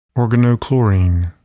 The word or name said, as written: organochlorine